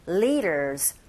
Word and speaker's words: leaders: 남녀 지도자들을 가리키는데 leaders도 여러분 귀에 들릴 때 leaders가 아니라 leader, R 발음이 아니라 L 발음이죠. 첫 음 자체가 li, li, leaders. 뒷사운드가 강몸, 양몸 사이에 또 껴있네요. 입대 R 소리. 그래서 귀에 들리는 소리는 leaders 이렇게 들리게 되죠.